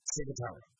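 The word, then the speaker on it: ciguatera: So, I want to say this right. It's Saratoga. That is the poison that these larger fish. Saratoga. Right, so like cigarette, Saratoga.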